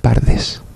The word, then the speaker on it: Pardes: Estas 4 letras hebreas forman juntas el acrónimo PRDS, que se pronuncia Pardes, jardín en castellano.